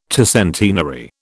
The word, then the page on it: tercentenary: British English